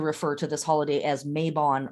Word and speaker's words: Mabon: Mabon, it's pronounced here in America, Mabon a lot. Mabon is the correct pronunciation.